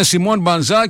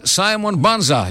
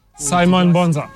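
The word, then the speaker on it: Simon Banza: Εμείς λέμε Σιμών Μπανζά και ο άνθρωπος ο ίδιος λέει το όνομά του και λέει Σάιμον Μπάνζα. Για δώσουμε μια φορά στην αρχή. – Σάιμον Μπάνζα.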